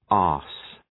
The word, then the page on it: arse: ɑ:rs